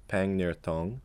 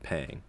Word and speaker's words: Pangnirtung: Well, the way it's spelled is PANGNIRTUNG. So people will often say like Pang Nirtong, or pretty much everyone just calls it Pang, and local people call it Pang as well.